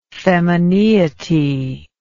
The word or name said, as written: femineity